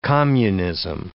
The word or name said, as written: communism